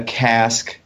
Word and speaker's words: casque: Like a casque. – So casque that we have for a hunter is, oh yes, that's CSK. – Yes, CASK, and then this is CASQUE, and it's pronounced the same, but yeah.